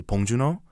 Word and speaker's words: Bong Joon-ho: Yeah, so the director Bong Joon-ho, or if I say it like how I would say Bong Joon-ho, his film, yeah, he won the Palme d'Or at the Cannes Film Festival, which is like the top honor.